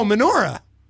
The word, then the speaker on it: menorah: Am I saying that right? – Memorial? Oh, menorah. – Menorah. I always say that right. – You light the menorah. – Right, I always say that wrong. – Just lighting. – If you're having trouble remembering how to say it, just think of the Adam Sandler song where he sings. David Lee Roth lights the menorah.